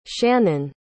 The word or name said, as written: shannen